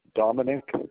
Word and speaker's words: Dominick: Dominic, that is spelled Dominick.